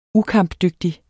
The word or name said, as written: ukampdygtig